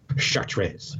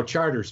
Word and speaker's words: Chartres: And, of course, I mispronounced Charters when I was there because I thought I was being smart and said Chartres, because you would think it would be French, right? No, Charters, man.